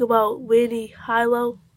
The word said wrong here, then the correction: Harlow: I don't know how to pronounce her name. – Harlow.